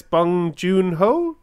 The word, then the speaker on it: Bong Joon-ho: Parasite's Bong Joon-ho.